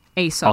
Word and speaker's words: Aesop: Aesop option. That's pronounced. – Aesop, sorry. Aesop's Fables.